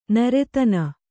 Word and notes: ನಿರ್ಧನ: kannada

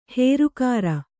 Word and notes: ಹುರಿಕರಿ: hurikari

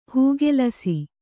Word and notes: ಹೂಗೆಲಸಿ: hūgelasi

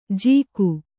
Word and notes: ಜೀಕು: jīku

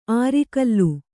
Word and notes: ಅರಿಗಲ್ಲು: arigallu

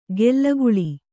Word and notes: ಗೆಲ್ಲಗುಳಿ: gellaguḷi

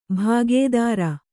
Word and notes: ಭಾಗೇದಾರ: bhāgēdāra

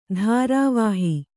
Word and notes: ಧೂರ್ವಹ: dhūrvaha

